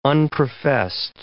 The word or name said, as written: unprofessed